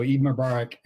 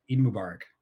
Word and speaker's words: Eid Mubarak: So Eid Mubarak, and did I say that right? – Yeah. – Okay, good. Eid Mubarak.